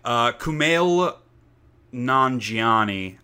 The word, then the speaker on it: Kumail Nanjiani: Kumail Nanjiani, I'm sure I'm saying his name wrong.